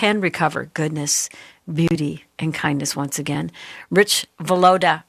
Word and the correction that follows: Villodas: I'm so psyched out that I've already pronounced his name wrong. Villodas, Rich Villodas joins me today to talk about his book, Good and Beautiful and Kind, Becoming Whole in a Fractured World.